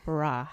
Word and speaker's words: Barak: The Barak, I think. – Barak. – How do you spell that? – Thanks, Obama. But Barak. – But Barak. It's translated. It's transliterated in English as B-A-R-A.